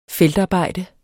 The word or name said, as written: feltarbejde